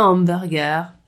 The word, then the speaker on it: hamburger: Le cinéma, le marketing, le popcorn, un hamburger, sans le H, on ne prononce pas le H en français, le musée.